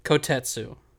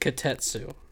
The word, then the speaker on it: Kotetsu: Kotetsu. – That's what I said. – You said Kotetsu.